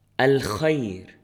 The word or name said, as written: الخير